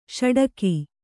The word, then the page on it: ಷಡಕಿ: Kannada